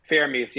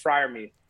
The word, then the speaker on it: Freiermuth: Is that how you pronounce it? – Freiermuth, yeah. Fryer. No, I think it's Freiermuth.